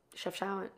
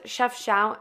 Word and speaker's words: Chefchaouen: Chefchaouen. – Yeah, oh my God, the Blue City. How did I forget that? – That city. I don't know if it's Chefchaouen.